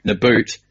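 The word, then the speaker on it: Nabbout: Everyone was saying Nabbitt, and now in Japanese it's Nabout.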